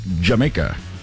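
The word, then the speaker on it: D'yer Mak'er: I know it's spelled like Dyer Maker, but it's pronounced Jamaica.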